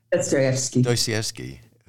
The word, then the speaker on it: Dostoevsky: Dostoevsky, who, you know, Crime and Punishment.